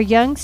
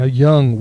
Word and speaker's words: Yonge: Now, Yonge, we had to look up how to actually pronounce this because it's spelled Y-O-N-G-E. I think you were thinking, too, it was just some... – Might be French. – Yeah. But actually, it's much closer to tongue, only without the U.